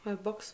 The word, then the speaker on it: Holbox: Da sah man, ich meine, es war auf Holbox mit der, oder Holbosch, Holbox wird es ausgesprochen, ich weiß nicht, ähm, Biolumineszenz sehen kann.